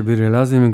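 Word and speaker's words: Biri Lazım: że ten tytuł wymawia się Biri Lazim, gdyż wokalista i wokalistka powtórzyli to w trakcie tego utworu parę razy.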